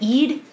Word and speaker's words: Ede: And then 2 months after Alan's murder on August 28th, 1979, a man named Kevin Ede, or Kevin Eddie, I don't know, it's E-D-E. How would you say that? Ede? Eddie? – I would say Ede. – Ede? Okay, I'm going to go with Ede.